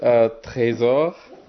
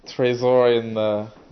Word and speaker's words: Tresor: My name is Tresor. Tresor for English speakers.